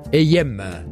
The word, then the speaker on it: EYM: Et cette semaine, tu as choisi le groupe AM. – Alors oui, ça s'écrit E-Y-M et ça se prononce AM.